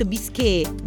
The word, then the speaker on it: bisque: No, ti ho detto bisque.